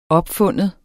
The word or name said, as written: OPFUNDNE